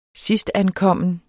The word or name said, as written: sidstankommen